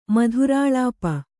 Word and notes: ಮಧುರಾಲಾಪೆ: madhurālāpe

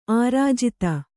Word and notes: ಆರ್ಜಿತ: ārjita

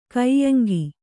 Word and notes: ಕಾಯಾಂಗ: kāyāŋga

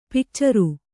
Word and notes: ಪಿಕ್ಚರು: pikcaru

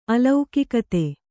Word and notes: ಅಲೌಕಿಕತೆ: alaukikate

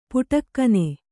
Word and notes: ಪುಟಕ್ಕನೆ: puṭakkane

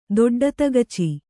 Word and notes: ದೊಡ್ಡತಗಚೆ: doḍḍa tagace

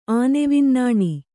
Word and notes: ಆನೆವಿನ್ನಾಣಿ: Kannada

